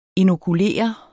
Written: inokulere

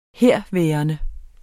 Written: herværende

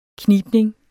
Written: knibning

